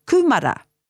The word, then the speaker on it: kumara: Like kumara. Kumara. – Kumara. – Kumara. Notice that the u is long. Kumara. And we sound out the ma and the ra.